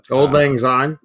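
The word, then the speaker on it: auld lang syne: Um, oh shoot auld uh, lang syne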